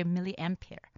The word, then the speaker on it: milliampere: Because the last time I said it wrong, but I'm glad people on WeChat, you guys got on there on Twitter telling me that I had to say milliampere battery.